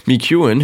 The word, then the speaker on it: McEwen: McEwen, who has now got 7 medals at Tokyo, that's right, I do make a few mispronunciations, but that wasn't one of them, 7 medals at Tokyo, and also goes with a nice little collection of 4 in Rio, adds up to 11, quick maths.